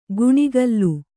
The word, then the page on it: ಗುಣಿಗಲ್ಲು: guṇigallu